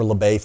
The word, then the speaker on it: LaBelle: And that's from LaBelle, Florida, or LaBay, Florida, if you don't want to pronounce the L's.